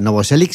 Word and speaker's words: Novoselic: O sea, Novoselic sí.